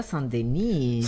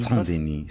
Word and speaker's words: Saint-Denis: Qué bien pronunciar Saint-Denis. – Saint-Denis.